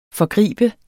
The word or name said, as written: forgribe